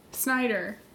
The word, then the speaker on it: Snyder: He played Principal Schneider. Snyder. – Whichever one. Was he related to a Rob or not? It all comes down to, is he a Rob Schneider relative? – I've been mispronouncing a lot of things lately. Principal Snyder, though.